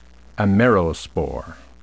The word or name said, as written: amerospore